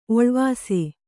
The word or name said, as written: ಓಲವಿಸು